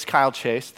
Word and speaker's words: Kyle Chase: My name, if you don't know me, is Kyle Chase. Thank you for that. It rhymes with smile face if you need help remembering it.